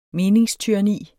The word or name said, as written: meningstyranni